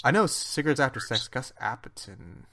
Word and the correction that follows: Dapperton: How do you spell that? D- Gus Dapperton.